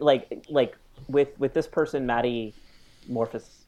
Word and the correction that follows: Maddie Morphosis: I looked it up online, so it's Maddie Morphosis. So, like, metamorphosis, but Maddie Morphosis.